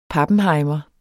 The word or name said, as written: pappenhejmer